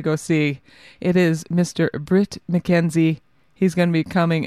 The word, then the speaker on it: Bret McKenzie: Bret, I will say it correctly, Bret McKenzie doing his Songs Without Jokes tour.